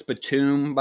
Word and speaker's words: Batum: and then it drops to 10 for Nicholas Bateman. – Right. – So, yeah. – It's Nicholas Batum, by the way, but I won't fault you for that because it's spelled like Bateman.